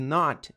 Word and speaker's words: not: Reparem que o T de not, geralmente, as palavras que terminam com T, termina com T, esse T geralmente é mudo, esse T sempre é mudo, desculpe.